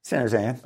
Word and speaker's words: San Joseian: Sam Liccardo is mayor of San Jose, the nation's 10th largest city, a longtime San Joseian. Is that how you say it? – More or less. – San Jose. – Yeah. It'll work. – How would you say it? – San Joseian. – Oh, okay.